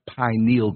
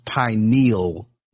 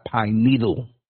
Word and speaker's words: pineal: If we're not dumbed down, if we don't have a calcified pineal gland, I just want to say it's pronounced pineal, not pine needle, okay? It sounds so ignorant when I hear people say pine needle. It's not a needle. It's a gland, pineal, okay?